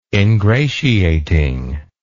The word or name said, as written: ingratiating